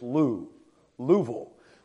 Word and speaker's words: Louisville: Someone says, you're not from around here, preacher, if you say there's no two syllables in Louis. It's Lou, Louisville.